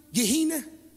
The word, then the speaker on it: Gehenna: You know, the word there means it means G E H E N N A. I don't know how to pronounce it. I think it's a long E. Gehenna. There you go.